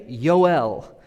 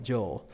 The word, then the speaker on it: Joel: His name means Yahweh is God, and it's actually pronounced, you ready? Yoel. But I'm not going to be pretentious and say the whole study is Yoel because everybody's called it Joel.